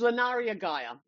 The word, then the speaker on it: Lunaria Gaia: So sometimes I get Lunaria Gaia, I get Lunaria Gaia, I get Lunaria. It's Lunaria Gaia. That's how you pronounce Lunaria.